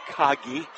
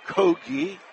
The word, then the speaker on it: Kogi: It's not Kagi. It's Kogi. Not spelled like Kroger's, but is got the long O.